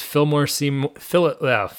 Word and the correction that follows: Philip Seymour Hoffman: Dude. Trying to mix up every syllable in that guy's name. Robin Williams versus Philip Seymour Hoffman.